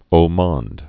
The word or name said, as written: haut monde